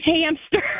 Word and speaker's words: hamster: I thought it was hamster. – I mean, I get though, because... – hamster. – Hamster. I always put a P sound in there.